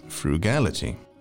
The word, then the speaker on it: frugality: Be careful with its pronunciation. Frugality.